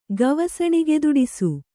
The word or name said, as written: ಗವಸಣಿಗೆದೊಡಿಸು